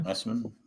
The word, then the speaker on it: Essman: Essman? Is that how you pronounce it? – Yeah. Essman.